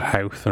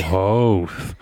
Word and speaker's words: Howth: And people do sometimes, uh, we, you know, visitors to this island flip out about how Howth is pronounced and why isn't it hoth? Or yeah, people ask for directions to hoth and be like, can't help you, buddy. Oh, Howth. But that's not nothing to do with Irish kind of phonology at all.